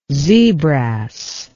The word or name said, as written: zebrass